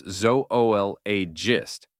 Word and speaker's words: zoologist: While the zoo part might tempt you to say it in one syllable, like the place where animals are kept, the two O's in zoologist and zoology should be separated, like in cooperate. So it's zoologist and zoolog. – Oh, good point.